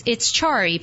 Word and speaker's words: Carii: Again, her name is Denise Hayman-Loa, and Denise is the CEO of Carii, and it's Carii pronounced, but it's spelled C-A-R-I-I.